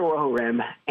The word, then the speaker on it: Rohirrim: Lord of the Rohirrim.